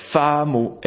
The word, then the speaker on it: Famo: et ça fait Famo, F-A-M-O, Famo, voilà, qui en espéranto veut dire comme fame en anglais.